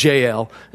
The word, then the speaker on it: Jael: He comes across this tent, and there's this sweet little thing got by the name of Jael. And I always remember Jael's name because it rhymes with nail.